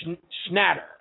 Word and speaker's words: Schnatter: So Papa John's, apparently the head of Papa John's Pizza, whose name is John Schnatter, or Schnatter, I should say, pronounce his name correctly, held a conference call with shareholders and all that other stuff.